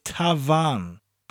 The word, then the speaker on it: tafarn: It's spelled T-A-F-A-R-N. The T-A-F gives us that tav sound, the F taking on the V, and the A-R-N gives us the arn sound. So instead of tavern, it's tavan, but with a rolling R.